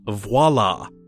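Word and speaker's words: voilà: Some people sort of use it in place of ta-da, and a lot of people mispronounce it as voila, which is not actually the thing, because they don't know the actual word and they're just ignorant, and I hate that. I hate when people say voila. But there is a V in the beginning of this word. The word is voila. V-O-I-L-A.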